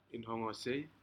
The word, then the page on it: inhogosei: Aneityum